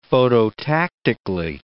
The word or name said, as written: phototactically